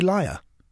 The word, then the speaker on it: LYR: How do you pronounce it? I say liar.